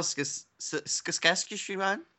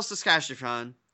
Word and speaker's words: Saskatchewan: Tisdale, Saskatchewan? – Yeah, that's how you do it. I believe that is phonetically all right. – Correct.